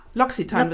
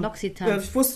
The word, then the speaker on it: L'Occitane: Ja, L'Occitane wird's ausgesprochen. L'Occitane. Wusste ich auch nicht. Das habe ich gelernt bei QVC, wo ich arbeite.